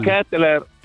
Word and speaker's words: Kettler: Kettler, visto come te l'ho detto, che si pronuncia così.